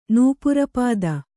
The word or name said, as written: ನೂಪುರಪಾದ